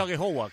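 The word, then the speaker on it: Aghehowa: Sí, yo sé que el apellido es un poco No sé si lo he pronunciado bien, pero he dicho Agejogwa, ¿cómo se pronuncia? – Sí, bien, bien, la verdad que muy bien, así, muy bien.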